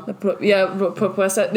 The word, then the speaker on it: Propuesta: It is called La Propuesta. Is that how you say it? That's the hit and run one. – Propuesta. Yeah, it's about the hit and run one is it's feels the longest.